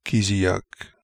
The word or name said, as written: Keeziak